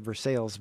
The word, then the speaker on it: Versailles: It looks like the name of a French city pronounced Versailles. Dutch transplant to Versailles, set me straight, however, on what to call her town. – No, it's Versailles. – Yeah. – Versailles is in France, you know.